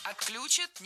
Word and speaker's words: отключат: Отключат, неверно, правильно, отключат. Что интересно, в глаголах с корнем ключ. Ударение на этот самый корень никогда не падает.